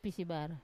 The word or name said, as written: pisibara